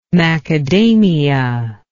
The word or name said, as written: macadamia